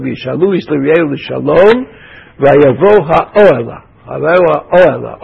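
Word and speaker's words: האהלה: Oela is one of those words in Hebrew which is accented on the pre, ante, penultimate, ante, penultimate syllable. Those of you who want to be Baalei Kriah, you have to know that.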